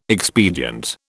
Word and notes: expedient: British English

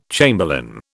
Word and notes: chamberlain: British English

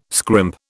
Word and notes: scrimp: British English

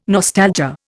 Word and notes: nostalgia: Indian

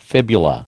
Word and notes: fibula: American English

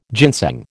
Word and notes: ginseng: American English

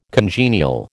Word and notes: congenial: American English

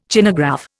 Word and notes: Chinagraph: Indian

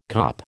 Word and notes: kop: American English

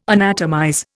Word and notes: anatomize: Indian

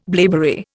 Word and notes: blaeberry: Indian

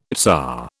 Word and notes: tsar: British English

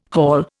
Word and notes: hall: Indian

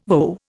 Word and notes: VO: Indian

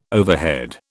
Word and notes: overhead: British English

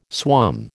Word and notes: swum: American English

